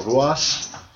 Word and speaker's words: Ruas: Pete Dunne and Arturo, I'm going to screw his name up, Arturo Ruas. – I think you said it right. – It's R-U-A-S, but he pronounces it with an H.